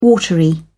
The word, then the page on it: watery: British English